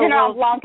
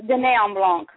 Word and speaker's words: Dîner en Blanc: Okay, I'm glad that you pronounced that properly. – It's not Dîner en Blanc, it's Dîner en Blanc.